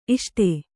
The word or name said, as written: ಇಷ್ಟೆ